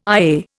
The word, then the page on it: IA: Indian